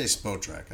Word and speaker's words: Spotrac: I do know this, that the Bears are spending, according to Spotrac? – I say Spotrac. I go long O on Spotrac.